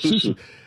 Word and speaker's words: susu: He's telling us about SUSU. Am I pronouncing it correctly? – It's SUSU.